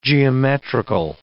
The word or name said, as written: geometrical